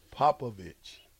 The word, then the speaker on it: Popovich: It's Popovich.